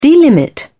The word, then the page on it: delimit: British English